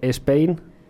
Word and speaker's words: Spain: Un ejemplo sería Spain, S-P-A-I-N, que los españoles tienden a pronunciarlo Spain, añadiendo una E antes de la S. – O sea que yo si digo Spain, no lo estoy diciendo muy muy bien, ¿no? – No, es Spain.